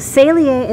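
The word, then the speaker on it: Saleae: And we found the sound Salier, and we really liked the sound of that, but we couldn't for the life of us spell it. – Good enough reason. So Salier is how you say it.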